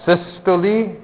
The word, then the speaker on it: systole: They're called systole, and the second one is diastole. That's how it's pronounced.